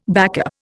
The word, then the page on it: backup: Indian